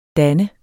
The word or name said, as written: danne